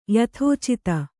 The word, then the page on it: ಯಥೋಚಿತ: Kannada